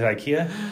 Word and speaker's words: IKEA: Is it IKEA? Yeah. – Yeah.